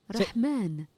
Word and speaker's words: Rahman: Haseem Rahman Jr. Yeah, so there's definitely no K.